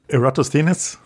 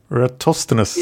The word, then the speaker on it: Eratosthenes: And that was, you know, you say his name. – Eratosthenes? – Yeah, I'd say. – Eratosthenes. – Yeah, either one will work.